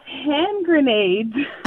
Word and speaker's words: hand grenades: and I pronounced it Hans Granatus, and everybody was so perplexed, like, what is she trying to say? And finally, somebody looks at the card and says, it's hand grenades.